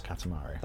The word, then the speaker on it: Katamari: Did it all start with the Katamari games? – I was about to say, is it about that game that I can't really pronounce? – Katamari.